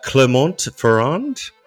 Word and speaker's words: Clermont-Ferrand: Clermont-Ferrand? – Yeah.